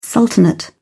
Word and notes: sultanate: British English

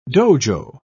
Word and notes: dojo: British English

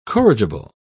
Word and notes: corrigible: British English

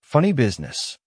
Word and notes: funny business: American English

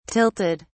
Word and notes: tilted: British English

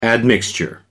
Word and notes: admixture: American English

